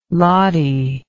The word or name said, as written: lottie